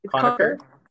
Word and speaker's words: Conacher: Is it Conacher? – Conacher.